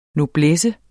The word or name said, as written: noblesse